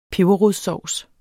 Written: peberrodssovs

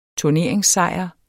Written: turneringssejr

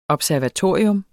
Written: observatorium

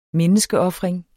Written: menneskeofring

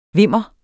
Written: vimmer

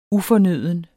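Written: ufornøden

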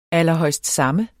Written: allerhøjstsamme

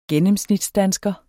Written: gennemsnitsdansker

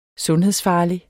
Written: sundhedsfarlig

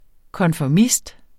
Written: konformist

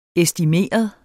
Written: estimeret

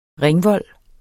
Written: ringvold

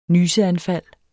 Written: nyseanfald

